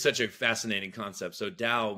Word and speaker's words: Tao: the Tao, is that correct? – Yeah, the Tao. The Tao. It's spelled T-A-O, but it's pronounced. Yeah, Tao, with the D, okay.